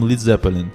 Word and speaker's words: Led Zeppelin: Я был доволен именем Led Zeppelin. Что-то было сродни Iron Butterfly, железная бабочка. Буква А была потом изъята из слова Lead. Так что беспечные американцы не будут теперь произносить слово как свинец.